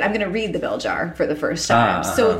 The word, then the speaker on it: Belzhar: I think it's Belzhar is the pronunciation. It's Belzhar.